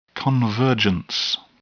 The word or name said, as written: convergence